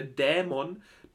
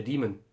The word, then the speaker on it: Dämon: Der Demon. – Der Dämon. – Der Dämon. – Der wird nicht Demon ausgesprochen. – Der nicht Demon? – Der Dämon. – Ah. – Weil er wirklich D-A-E ausgesprochen, ausgeschrieben wird.